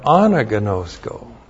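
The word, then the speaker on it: Anagnosko (ἀναγινώσκω): And then the conjunction kai is continuative, and now we have anagnosko. A-N-A-G-I-N long O-S-K long O. Anagnosko.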